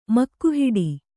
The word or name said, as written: ಮುಖಹೇಡಿ